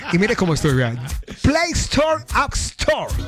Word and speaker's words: Play Store: Play Store.